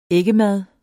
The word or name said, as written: æggemad